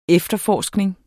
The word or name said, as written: efterforskning